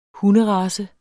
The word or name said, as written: hunderace